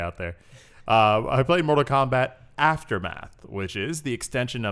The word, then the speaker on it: Mortal Kombat: Sorry, I mispronounced that. Let me try that again. Mortal Kombat! I am contractually obligated to say it that way. – You gotta really hear the K.